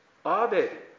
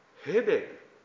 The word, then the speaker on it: ヘベル: アベルはより正確に発音するとヘベルで、コヘレトの言葉の冒頭に出てくる空、空という字を書きますが、空を意味します。